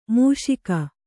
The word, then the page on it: ಮುಷ್ಕ: kannada